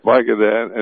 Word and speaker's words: Vicodin: And yet there's another story saying that after the massacre, Holmes calmly told detectives he'd taken 100 milligrams of the prescription painkiller Vicodin. Is that how you pronounce it? – Vicodin.